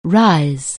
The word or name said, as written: rise